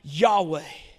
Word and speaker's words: Yahweh: In the Old Testament, the people of Israel called God Yahweh. And you spell that Y-H-W-H. And when it's written out, there's no vowels.